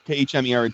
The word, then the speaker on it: Khmer: I'm not sure if I'm pronouncing this correctly. Khmer. It's the Khmer. – Khmer. It's the Cambodian language, has 74 letters.